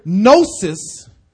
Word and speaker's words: gnosis: That's why it's called Gnosticism, because the Greek word gnosis, the G is silent, meant to know.